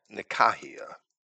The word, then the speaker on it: Nicaea: Nicaea. I'd rather pronounce that Nikaia, as it should be pronounced in Greek.